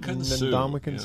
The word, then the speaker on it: Ndamukong: Rams getting Beckham and Ndamukong Suh. Did I pronounce that right? – Ndamukong Suh.